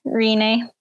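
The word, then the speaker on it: Rinne: Rene.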